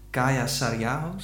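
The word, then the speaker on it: Kaija Saariaho: maar we beginnen even met ehm, een stuk dat jij hebt meegenomen van Kaya Sariaho, zeg ik dat goed? Ja, zeg je.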